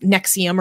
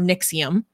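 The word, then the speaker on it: NXIVM: but it's pronounced Nexium or Nixium, which I have a little bit of an obsession with destructive cults and destructive groups.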